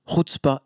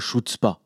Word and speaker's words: chutzpah: Euh, alors, on m'a appris à le prononcer, hein, c'est un authentique Ashkenaz qui m'a expliqué, mais je ne me souviens pas exactement, pardon pour les ashkénazes si je prononce mal, Chutzpah, Chutzpah Hebdo, hein, et pas Chutzpah.